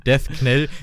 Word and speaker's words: Death Knell: Ich war, Death Knell, ich glaube Death, Death Knell. wäre das. Death Knell, ja, ist wie, wahrscheinlich wie das Knight.